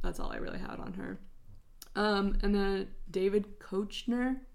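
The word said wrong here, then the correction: Koechner: Is it Kochner? – It's Kechner. – Kechner.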